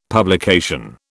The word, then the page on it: publication: British English